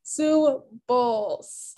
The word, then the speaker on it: Bowles: Bowles, like a cereal bowl. – Sue Bowles.